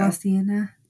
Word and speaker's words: La Cienega: La Cienega. There we go. – La Cienega. No, see, I don't think you pronounce the G. La Cienega, I think, is how it is.